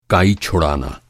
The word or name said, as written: काई